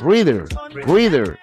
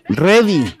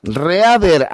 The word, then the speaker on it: Reader: Es Rider, no. Es Reader. ¿Cómo se pronuncia? Reader. Pero es... Oye, Ready. No, es Reader. Así se escribe. Reader. BG. No, tampoco es Readi, no, Readi es listo, ¿ah?